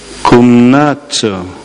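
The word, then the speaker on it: 굽나처 (γύμναζε): 자, 이 동사는 저 발음해 볼 테니까 영어에 뭐가 유추되는지 한번 보세요. 굽나처. 다시요. 굽나처. 김나지움 유럽의 대학 진학을 위해서 있는 그 중고등학교를 김나지움이라고 그러죠.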